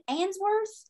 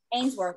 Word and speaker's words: Ainsworth: Is it Answorth? Is that how you say it? Answorth. – Ainsworth.